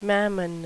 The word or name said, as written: mammon